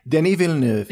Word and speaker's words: Denis Villeneuve: Is that Denis Villeneuve? – Denis Villeneuve, he's French-Canadian, and I heard that pronounced that way by a person in the know once, and I'm going with it.